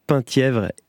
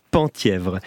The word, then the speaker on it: Penthièvre: Et on m'annonce également que le fort de Penthièvre se prononce bien Penthièvre et non, mea culpa, Penthièvre.